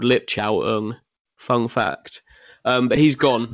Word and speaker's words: Ng: But Perry, if he's of New Zealand origin, which I believe he might be, it's Ung.